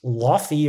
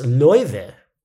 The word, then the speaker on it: Laufey: Her name, you might think it's pronounced Laufey or Laufey. You might think it's pronounced Laffy Taffy, but no, it's pronounced Loive.